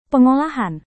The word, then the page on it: pengolahan: indonesian